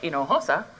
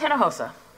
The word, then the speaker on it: Hinojosa: I wasn't asking for a teacher to ever say Hinojosa, maybe just Hinojosa. That's all right. We can phonetically do that.